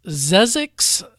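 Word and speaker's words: Xexyz: It's Zezix for the NES, not to be confused with Zezix Road, which is between Los Angeles and Las Vegas. It's X-E-X-Y-Z is how this game is spelled.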